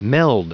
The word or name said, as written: meld